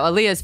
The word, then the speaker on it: Alia: And I'm like, and he said my name, like, that's how you say it. It's Alia. I'm like, yeah. – Oh shit, it's Alia? – No, no, Aaliyah's fine. Aaliyah's fine.